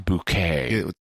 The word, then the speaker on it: Bucket: Her name is Frances Bucket, but she wants it pronounced Bouquet.